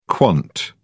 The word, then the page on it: quant: British English